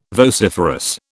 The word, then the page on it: vociferous: British English